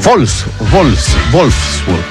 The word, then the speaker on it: Wolfsburg: No, ese Wolfsburg, porque la W se pronuncia como V corta, ¿no? O V, ¿no? Se dice V.